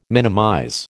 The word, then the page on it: minimize: American English